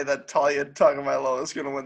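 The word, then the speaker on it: Tua: I wanted to see two quarterbacks, two future Heisman winners, one guaranteed future Heisman winner in Talia. That's how his name's pronounced, right? – Yeah, Talia.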